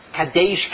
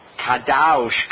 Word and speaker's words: Kadosh: If you're a Litvak, you pronounce it as an A. Kadesh, Kadesh, Kadesh. If you're German, Au, Kadaush, Kadaush, Kadaush. If you're not German, you're not a Litvak, let's say you're Polish, Kadosh, Kadosh, Kadosh.